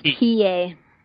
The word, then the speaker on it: Hiei: On Mount High specifically? – It's actually pronounced Hiei. – Hiei. Oh, sorry.